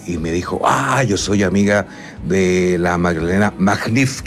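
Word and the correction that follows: Max-Neef: que me acabo de dar cuenta que se dice así, pues yo decía Magdalena Magnif. – No, es Max Neff, ella le dice Max Neff.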